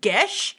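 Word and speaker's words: geas: Apparently, you pronounce that word Gesh. – Gesh? I've looked that up before. – It's like Irish.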